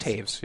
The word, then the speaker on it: Toews: But is it T-O-E-W-S? – T-O-W-E-S, yeah. But it's like pronounced Taves, yeah. – Yeah, like Jonathan Taves.